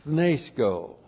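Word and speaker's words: apothnēskō: Looks like that in the Greek, that's A-P-O-T-H-N, long E-S-K, long O, apothnesko.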